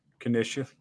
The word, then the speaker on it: Canisius: Canisius.